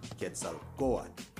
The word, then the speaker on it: Quetzalcoatl: How do we pronounce it? Well, it's pronounced Quetzalcoatl. Once again, I got it right.